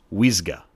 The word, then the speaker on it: uisge: The spelling is U-I-S-G-E, and I believe it is pronounced Wisga.